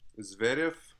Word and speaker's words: Zverev: Zverev.